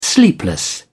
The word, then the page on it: sleepless: British English